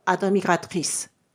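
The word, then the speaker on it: admiratrice: ですので、あの、発音すると、アドミカトリス。アドミカトリス。これが女性系単数。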